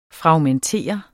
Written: FRAGMENTER

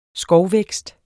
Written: skovvækst

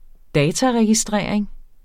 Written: dataregistrering

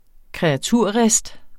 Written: kreaturrist

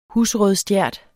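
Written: husrødstjert

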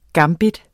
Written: gambit

